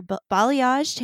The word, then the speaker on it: balayage: She believes a woman's best accessory is her balayaged hair. Did I say that right, Holly? – Yeah, you did perfect.